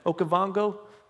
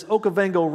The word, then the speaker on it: Okavango: Now, there's, there's a lot of cool stuff out there, but particularly these lions in Africa, and they are the, the Okavango, Okavango, I think is how you say it, Okavango.